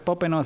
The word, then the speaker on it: Popenoe: Y para quienes lo quieran buscar, Popeno se escribe Popenoe, con E al final, ¿verdad? – Sí, Popenoe.